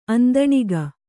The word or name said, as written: ಅಂದಣಿಗ